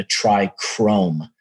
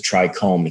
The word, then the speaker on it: trichome: Everyone was calling it a trichrome. no one knew that it was a trichome, except when Rob wrote the book and was like, it's a trichome, not a trichrome.